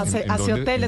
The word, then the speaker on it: AC Hotel: Hace hotel, está bien, aquí decimos todos, hace hotel.